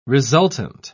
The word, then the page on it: resultant: British English